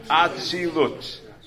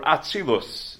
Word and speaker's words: Atzilut: Atzilut, or Atzilus in the Ashkenazi pronunciation. Atzilut in the more Sephardic.